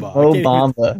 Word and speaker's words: Mo Bamba: Bo Mamba is pretty good. Mo Bamba. – No, it's Mo Bamba.